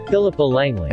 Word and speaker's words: Philippa Langley: Is that how you pronounce it? Philippa Langley? – Philippa Langley.